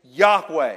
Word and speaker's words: Yahweh: And so if you'd like to spell it out better, so you remember how to say it, it's YAHWEH, Yahweh.